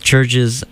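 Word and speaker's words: CHVRCHES: And that's Churches spelled like the U is a V.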